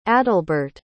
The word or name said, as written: adalbert